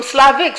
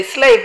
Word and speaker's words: Slav: Slav comes from Slavic, from Slavia. That's a place. Origin beings, indigenous beings, cannot be Slav. Slavic, because pronunciation, they say slave, but it's actually Slav. S-L-A-V. Slavic, Slav.